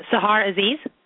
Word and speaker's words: Sahar Aziz: Sahar Aziz.